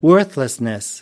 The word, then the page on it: worthlessness: British English